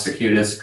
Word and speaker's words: circuitous: That was great perspective, and I think you did pronounce circuitous correctly.